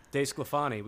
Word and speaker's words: DeSclafani: DeSclafani.